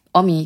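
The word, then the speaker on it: Homme: Vor allen Dingen kommt dann Josh Om. Wie spricht man seinen Namen aus? Omi, genau. Josh Omi kommt rein.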